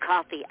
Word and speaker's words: Ko-fi: It's pronounced coffee. I found that out. That's how you pronounce it.